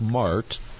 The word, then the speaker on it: Marthe: of you and Mart, and she has an unusual spelling of her name, so it's pronounced like Mart. – Yes. – But it's spelled M-A-R-T-H-E.